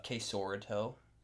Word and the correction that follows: quesarito: And they're like, you mean the quesarito?